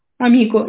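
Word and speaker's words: amico: Э, а, еще проблемы э, бывают с ударением, хотя мне не сильно понятно, потому что в итальянском языке чаще всего ударение падает на предпоследний слог. – Все должно было быть нормально. И простые слова, как, например, «амико» и «мартина», очень часто какие-то люди изначального уровня произносят вроде Амико и Мартина.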